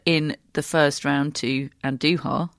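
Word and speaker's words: Andújar: I've just realised, uh, courtesy of Matt, that I've been pronouncing Andujar's name wrong for the entirety of his career, which is a very long time. So it's Andujar, not Andujar, Matt.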